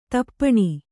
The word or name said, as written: ದೀಪನ